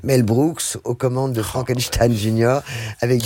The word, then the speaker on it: Frankenstein: Dr. Frankenstein. – Frankenstein. – You're putting me on. – No, it's pronounced Frankenstein.